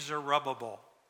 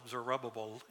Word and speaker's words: Zerubbabel: And then you have this four-syllable Zerubbabel in there somewhere.